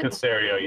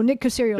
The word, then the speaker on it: Caserio: But also, Joe Judge is there, and from what I understand, Is it Nick? I never pronounce his name correctly. Nick Caserio with the Patriots. – Nick Caserio, yeah. – There you go, Nick Caserio.